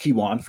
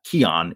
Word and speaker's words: Keyone: It's pronounced Kewon for anyone who's out there. K-E-Y-O-N-E. So not Keon, it's Kewon. But he's a kid who, Joe, has one of the most dynamic nicknames in all of South Florida, Lights Out.